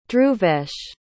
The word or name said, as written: dhruvesh